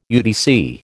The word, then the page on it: UDC: American English